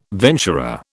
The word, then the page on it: venturer: British English